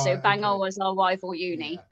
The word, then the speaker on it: Bangor: Just for the Americans watching here, Bangor is the name of the town. That is how it's pronounced. – It's spelled Bangor, yes. But like, yes. Yes, it's pronounced Bangor.